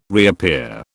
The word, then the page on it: reappear: British English